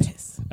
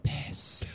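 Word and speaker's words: PiS: The big star of that party now is Piss. Mm-hmm. From Poland. – Piss. – Like say it like Macklemore. Piss.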